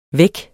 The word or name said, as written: væk